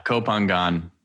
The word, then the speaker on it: Koh Phangan: Koh Phangan.